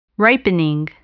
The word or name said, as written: ripening